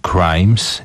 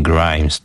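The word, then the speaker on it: Cr3mes: Jest taka grupa Crimes, nie Grimes, tylko Crimes. I jest taki sekret, że to pisze się zamiast E jest trójka. Crimes, właśnie tak.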